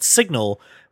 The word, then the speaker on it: SGNL: So there is a Kickstarter for a product called Signal, which apparently can't afford the extra letters because it's spelled S-G-N-L.